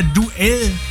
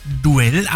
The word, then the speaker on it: Duel: Dieser Song wird ja Duell geschrieben von Propaganda. Und ich habe mir gedacht, der heißt dann auch Duell. Aber nein, Duel wird das Ding ausgesprochen.